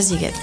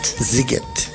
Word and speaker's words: Sziget: Se escribe Siget. S-Z-I-G-E-T y se pronuncia Siget. – Siget.